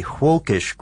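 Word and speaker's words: Völkisch: And this was an occult society, and it was also something called a Wolkish group. And that's with a V, but pronounced the German way, right?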